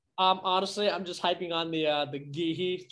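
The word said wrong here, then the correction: Guéhi: train. I can't pronounce his name. – Gehi, thank you.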